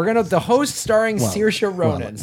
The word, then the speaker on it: Saoirse: Of Sarsay Rohans. – Sarsay Rohans. For the love of God, please pronounce it correctly. So here it is right now. – Okay. Give your sound. – Saoirse. Saoirse. – Saoirse. Saoirse Ronan.